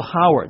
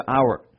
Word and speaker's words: hour: 如果人们按照和传承这个习惯，就会将很多本来也发音的字母的 h 也会消失去书写，所以呢省略掉了，导致于这个 h 字母发音。我们看到很多例子，比如说小时不叫做 hour 叫 hour，比如说诚恳不叫 honest 叫 honest。